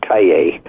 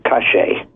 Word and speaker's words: Calle: In any other country, Spanish-speaking country in the world is Calle, but in Argentina, it's pronounced cache.